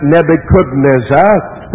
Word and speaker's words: Nebuchadnezzar: Nebuchadnezzar, or some people pronounce it Nebuchadnezzar, whichever, it's the same fella, king of Babylon, some 600 years before Jesus was born, and that's significant.